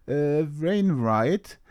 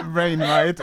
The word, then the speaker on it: Wainwright: Tiergarten von Rufus Wainwright. Wainwright, um genau zu sein.